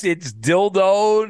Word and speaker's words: Dildo: Is that how you really pronounce it? – Yeah, it's Dildo, Newfoundland. That's the name of the town.